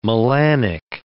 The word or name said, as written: melanic